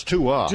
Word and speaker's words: Tua: Tui Tagovailoa. I understand today I was mispronouncing it. I got to use Getting closer. – Yeah, closer. – He blew the first name, though. It's Tua, not Tui.